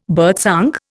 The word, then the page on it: birdsong: Indian